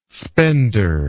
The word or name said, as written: spender